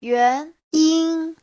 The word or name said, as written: yuan2yin1